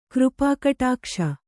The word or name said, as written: ಕೃಪಾಕಟಾಕ್ಷ